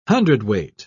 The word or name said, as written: hundredweight